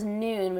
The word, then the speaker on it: Nuun: And then Ultima is another one that's a little bit more easily available, as well as Noon, which is spelled N-U-U-N.